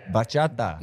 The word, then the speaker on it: bachata: No, bachata. Like, say it a little quicker. Like, bachata. – Bachata. – Say it like you mean it. – Bachata. – Put your hip into it when you say it.